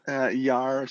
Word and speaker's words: Yar: Yar.